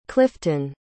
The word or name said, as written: cliffton